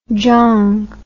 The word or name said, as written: jong